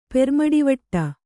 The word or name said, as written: ಪೆರ್ಮಡಿವಟ್ಟ